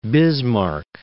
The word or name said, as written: Bismarck